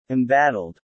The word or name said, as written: embattled